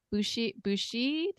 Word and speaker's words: Buchey: And we are so happy and I am so excited to have Lisa Bushi. Did I say that right? – Yes. – Bushi.